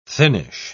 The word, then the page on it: thinnish: British English